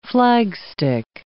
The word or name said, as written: flagstick